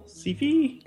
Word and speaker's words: Sci-Fi: Sci-Fi? – Yeah.